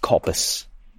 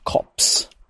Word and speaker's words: coppice: And he is a little bit in one of them where it's like, it was never pronounced coppice. Even if it's spelt like that, it was always just cops. Even if it's spelt coppice, it's it was always just said cops.